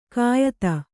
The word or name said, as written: ಕೆಯ್ದು